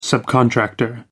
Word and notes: subcontractor: American English

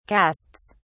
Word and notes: casts: British English

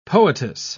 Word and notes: poetess: British English

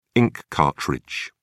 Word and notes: ink cartridge: British English

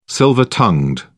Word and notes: silver tongued: British English

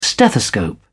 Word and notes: stethoscope: British English

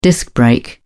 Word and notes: disc brake: British English

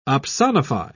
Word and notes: opsonify: British English

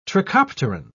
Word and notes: trichopteran: British English

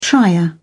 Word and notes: trier: British English

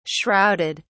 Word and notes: shrouded: British English

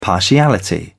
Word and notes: partiality: British English

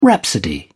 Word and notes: rhapsody: British English

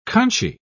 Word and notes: conchy: British English

